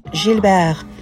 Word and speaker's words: Gilbert: Gilbert. So it's a soft G for Gilbert. Gilbert Bécard. But you've got the Bécard bit spot on. That sounds really good. So Gilbert Bécard.